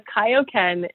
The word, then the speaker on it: Kaioken: So the Kaioken is something from Dragon Ball Z. – So I was mispronouncing it. I'm so sorry. – I know. No. It's totally fine. Most people think that it's Kayo Kenzie unless mm-hmm.